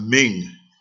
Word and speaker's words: Ming: Qing, Q-I-N-G, and we have Ming, M-I-N-G. Rhymes with ring, R-A-N-G, rhymes with sing, S-I-N-G, rhymes with ding, D-I-N-G, rhymes with fling, F-L-I-N-G. So, the old spelling for the Q-I-N-G was C-H-I-N-G, so that's Qing, and then the mainland Chinese authorities changed their spelling.